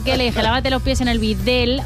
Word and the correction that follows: bidé: Que no se dice Videl. – Que no se dice Videl, se dice bidé.